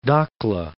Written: Dakhla

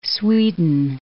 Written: Sweden

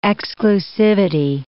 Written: exclusivity

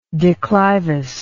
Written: declivous

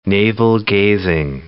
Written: navel-gazing

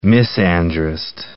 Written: misandrist

